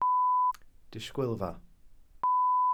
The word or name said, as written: disgwylfa